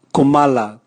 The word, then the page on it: kumala: Raga